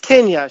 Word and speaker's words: Kena: It's Kenya. She said it's Kenya. Kenya. – Bridge of Spirit. – Kenya. Yeah, it's spelled K-E-N-A.